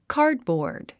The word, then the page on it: cardboard: kɑ́ːdbɔ̀ː r d; カードボード